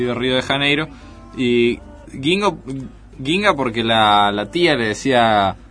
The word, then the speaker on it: Guinga: Quizás por el perfil bajo del tipo, que es Ginga. – Uh-huh. – Se escribe Guinga.